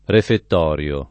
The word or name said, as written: refettorio